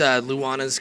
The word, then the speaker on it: Luana's: It's Luana's Coffee Yard. – Okay, I for some reason I keep wanting to call it Luna's, but I know we get a lot of Luna's, we get a lot of Luana's, but it is Luana's.